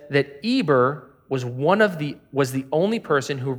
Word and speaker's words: Eber: Eber is also sometimes written with an H, like a silent H, like we say the word herbs, but we just say herbs, even though there's an H in it.